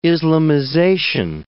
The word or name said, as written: Islamization